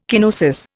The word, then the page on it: kenosis: Indian